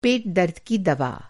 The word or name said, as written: पेट-दर्द